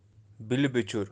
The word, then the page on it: bilbichur: Kashmiri